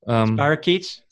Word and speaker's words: Spirochetes: Spirochetes.